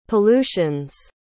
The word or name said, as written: pollutions